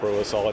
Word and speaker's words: Provisol: Tell us about Provisol. Am I even saying that properly? – Yes, you are. And thanks for the opportunity to talk about Provisol.